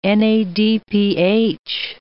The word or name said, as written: NADPH